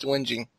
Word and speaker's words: whinging: Thank you for clarifying that it actually is pronounced whinging.